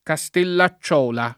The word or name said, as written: Castellacciola